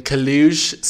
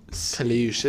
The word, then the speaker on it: Caleuche: The Kalush. – How do you spell that? – The, T-H-E. – Okay. – And then Kalush, C-A-L-E-U-C-H-E. Kalush.